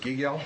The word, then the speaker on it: Gigiel: Also in support, Robert, I'm sorry if I pronounced your last name incorrectly. Gigale? – No, oh, why not? Thank you. – My name is Robert Gigiel, G-I-G-I-E-L, and